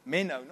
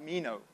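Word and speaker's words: meno (μένω): This word, if you abide in me and my words abide in you, that Greek word for abide is meno, not mino. Not, I guess you could pronounce it finding meno. But it's meno.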